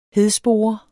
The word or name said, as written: hedspore